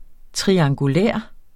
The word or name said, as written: triangulær